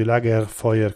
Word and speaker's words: Lagerfeuer: e quindi si legge Lagerfeuer con la R moscia.